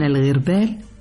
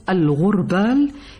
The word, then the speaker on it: الغربال: أقول لكم، وجه العجب في أننا نعرف أن الغربال، هكذا تنطق باللغة العربية الفصحى، أو الغربال كما ننطق الكلمة بالعامية، هو أداة ذات ثقوب ينقى بها الحب من الشوائب.